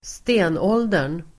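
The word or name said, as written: stenåldern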